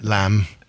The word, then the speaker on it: lamb: Know about that lamb. Yeah. The weird thing about that word is it has a B at the end in English, but it's pronounced the same as the German, lamb.